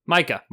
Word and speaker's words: Micah: Micah.